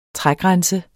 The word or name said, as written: trægrænse